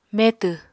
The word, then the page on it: metw: Apatani